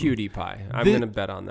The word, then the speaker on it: PewDiePie: It's spelled P-E-W-D-I-E-P-I-E. PewDiePie. – Like CutiePie. – I think so. Or PewDiePie. – No, you don't say CutiePie.